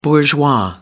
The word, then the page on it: bourgeois: American English